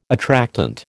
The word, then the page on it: attractant: American English